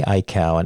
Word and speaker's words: Aikau: Well, for anyone listening who is not familiar with either Eddie Aikau, and Aikau is spelled A-I-K-A-U, and I've always said Rail's son, but it's Rail, or Rail, right? – Yeah, I'm sure she's heard it all too.